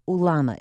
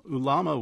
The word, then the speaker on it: ulama: This game of ulama, is that how you say it? – That's right. – Can you describe it to us? – Yes. So ulama was played as early as 1500 BC by the cultures of Mesoamerica, so Mexico and Central America.